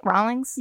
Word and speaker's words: Rawlings: Am I saying that right? Rawlings? You are? That's right.